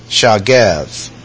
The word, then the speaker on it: shagab: The Hebrew verb, if you would go to a lexicon, and you would look in that lexicon for this particular word, which is spelled S-H-A-G-A-B, shagev, and it would say this.